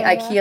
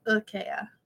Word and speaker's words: IKEA: Malin says we say it wrong. It's like IKEA. – Oh, yeah. We're never going to get that right. – Just in case you were wondering. And I'm butchering that in Swedish also.